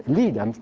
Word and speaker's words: Lead: So what he does the next time, he puts a bigger tank deep underground in a Homestake gold mine in lead, which is pronounced lead, I'm told, even though this is a Dakota thing.